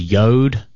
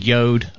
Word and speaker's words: Jord: Apparently, the name of your watch is Yod. – Oh, I'm saying it incorrectly. – Yeah, you're Yod. Saying it wrong. Yod, like toad. – Even though there's an R in it? – Apparently. – J-O-R-D, saying it's Yod?